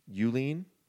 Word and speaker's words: Hjulin: So, we joke today because the reason he changed it to Julene is because nobody could pronounce with the silent H, Eulene.